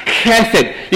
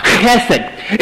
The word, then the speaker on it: chesed: How many of y'all have heard that old Hebrew word, chesed? No, y'all have heard me say that a few times. Chesed. You got to spit on somebody when you say it and you're not saying it right. Chesed.